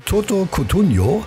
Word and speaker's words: Toto Cutugno: So, und am allerliebsten, schreibt die Corinna, hört sie von Toto Cutugno. Habe ich das richtig ausgesprochen? Das ist ja Italiener. Oh, das hast du fantastico ausgesprochen, aber es war Umberto Tozzi.